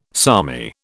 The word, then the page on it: salmi: British English